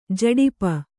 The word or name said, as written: ಝಡಪ